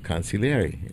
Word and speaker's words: consigliere: And it's called Consigliere, and that's how you say it in Italian.